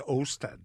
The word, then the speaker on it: Osta: I'm sure it's pronounced Osta, isn't it? Yeah. I thought Osta was an Irish word, a Gaelic word.